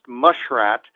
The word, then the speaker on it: muskrat: to eat muskrat, or as it's sometimes pronounced, mushrat, on Fridays in Lent.